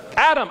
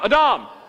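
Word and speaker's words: Adam (אדם): It's a name that rhymes with Padam. Adam, Adam is the Hebrew word.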